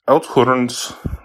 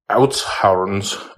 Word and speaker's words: Oudtshoorn: Anyways, Outhorns, I'm, I'm saying it wrong. I have to remember ha ha. Oudtshoorn's